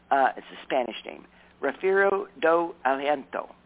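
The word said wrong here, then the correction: Rafeiro do Alentejo: Alentejo. A Refiero do Alentejo.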